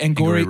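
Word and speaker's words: Angourie: honestly, him and is it Angouri? That's how you pronounce it, right? – Angouri Rice.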